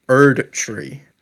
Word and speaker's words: Erdtree: Erdtree. Say it right now. – Shadows slowly. – Of the Erdtree expansion. Erdtree. – I don't like the enunciation, but I'll let it go. – I'm British. What do you want from me? – I want you to say it properly. You're British.